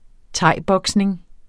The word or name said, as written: thaiboxing